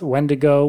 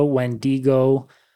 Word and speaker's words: Wendigo: So the physical appearance of a Wendigo can vary based on the different pronunciations of its name, so when I was researching, I found a lot of different ways to pronounce it. It's Wendigo, Wendigo, Wentico.